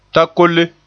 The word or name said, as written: takolle